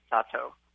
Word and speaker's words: Sato: The piece is Bifu by Somei Satui. Am I saying that right? – That sounds pretty close. I think the last name is pronounced Sato.